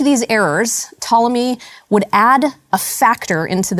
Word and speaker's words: Ptolemy: He had a contemporary called Ptolemy, or Ptolemy, if you can't pronounce silent letters.